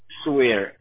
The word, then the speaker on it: Suire: Suire, S-U-I-R-E. And my first name is spelled... My first name is spelled K-R-I-S-T-I-A-N.